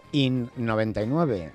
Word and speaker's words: IN99: ¿Cómo se pronuncia, en español o en inglés? – De las dos maneras, IN99. Nosotros llamamos IN99, sí.